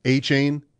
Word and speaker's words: Achane: That is how you say it, Achane.